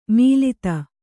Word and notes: ಮೀಲಿತ: mīlita

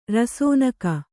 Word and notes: ರಸೋನಕ: rasōnaka

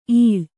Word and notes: ಇಳ್ಳು: Kannada

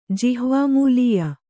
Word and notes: ಜಿಹ್ವಾಮೂಲೀಯ: jihvā mūlīya

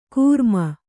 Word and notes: ಕೃಮಿ: Kannada

